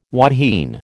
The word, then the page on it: wahine: American English